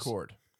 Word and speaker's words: Kord: That's your last Cord? – Name? – Cord? – Cord Industries. – Cord. Yes, K-O-R-D, Cord.